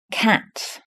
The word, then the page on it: cat: British English